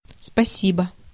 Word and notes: спасибо: /spʌ.ˈsʲi.bə/

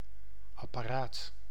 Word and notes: device: /dəˈvaɪs/

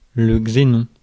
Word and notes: xénon: French

